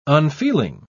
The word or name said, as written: unfeeling